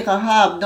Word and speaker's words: Rahab: C'est prononcé Rahab dans la généalogie de Matthieu.